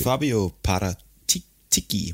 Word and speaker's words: Fabio Paratici: Øh, Fabio Paratici, må det så være.